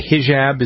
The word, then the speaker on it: hijab: The Middle Easterners call them, is it a hijab? Is that how you pronounce that? – Hijab. – Hijab, uh-huh. Yeah. – Hijab, right.